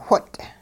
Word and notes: whot': hupa

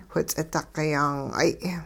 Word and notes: whi-ts'iduqi-yaang'ay: hupa

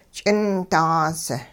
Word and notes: ch'indaas: hupa

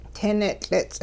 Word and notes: ting nitL'its': Hupa

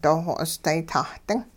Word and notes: dooxo'osday-tah-ding: hupa

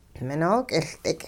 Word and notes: minook'iLdik': hupa

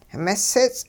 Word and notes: misits': hupa

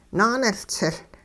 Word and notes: naaniLtsiL: hupa

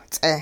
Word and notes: ts'eh: hupa

